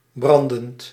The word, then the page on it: brûlant: [bʁy.lɑ̃]; France